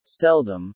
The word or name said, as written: seldom